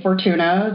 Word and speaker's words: Fortuna: So I have on today's episode, I have TJ Fortuna.